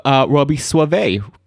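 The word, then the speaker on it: Robby Soave: Today we have Robbie Suave, right? – Did I pronounce it right? Is that how we pronounce it? – That is how you pronounce it.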